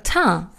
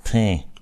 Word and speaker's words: Teint: Der Teint. – Teint.